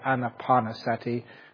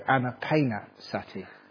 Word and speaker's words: Anapanasati: And please do not mispronounce that, because some people, they don't do Anapanasati, they do Anapanasati. Mindfulness along with the pain.